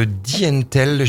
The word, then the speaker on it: Dntel: Un très bel album de musique semi-électronique, semi-ambiante de Dientel. Je vais prononcer ça comme ça, ça s'écrit D-N-T-E-L.